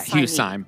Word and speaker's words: Syme: It's spelled S-Y-M-E. – Syme. – I don't know if that, is it Syme or is it Hugh Syme.